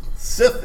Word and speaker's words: Sith: Sith is correct. – Be careful how you pronounce it on the radio. That's correct. – yes. yes. Get that H in there.